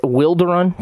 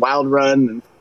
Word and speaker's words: Wilderun: But yeah, we do get a lot of people saying Wild Run. And, I've seen some YouTube videos where people have said that. And Wilderun is how we've been pronouncing it.